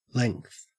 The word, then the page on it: length: Australian English